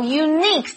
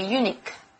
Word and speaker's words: unique: How unique 这个字呢？大家重音呢一定要念对哦，因为我常会听到有人听，you know, a lot of people actually pronounce this word wrongly. They say unique, but it's actually unique.